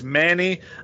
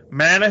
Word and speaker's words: Many: But I'm gonna trust your pronunciation that it's Manny. You know, it's spelled M-A-N-Y, but, you know, down in Creole country, it's probably something like Manny. Manny, I live out there in Manny, you know, where they grow them linebackers like Tackett Curtis.